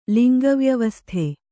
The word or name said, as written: ಲಿಂಗವ್ಯವಸ್ಥೆ